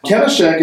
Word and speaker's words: Kenishak: Kenishak is about 13 letters long, is that right? Am I saying it right? – You are saying it perfect.